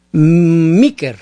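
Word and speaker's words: Meeker: M-E-E-2-M-2-E. M-E-E-K-E-R. – Ah, Micker. – Oh, Micker sería Micker en español, sí. Con doble E, Micker. – Ajá, ¿y es street o es avenue o qué es? – Micker Street.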